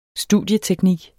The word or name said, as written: studieteknik